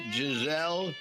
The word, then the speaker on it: Guiselle: G-U-I-S-E-L-L-E. Todd, am I saying that right? Giselle? – Yeah, that's right.